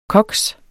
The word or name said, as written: koks